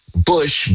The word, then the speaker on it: Bush: Now, my name is spelled C-O-R-B as in boy, Y. Corby Bush, B-U-S-H, Corby Bush Ministries on Facebook.